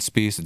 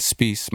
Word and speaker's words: Spiehs: It's Dr. Justin Spies. It's Dr. Justin Spies. My last name is spelled S-P-I-E-H-S.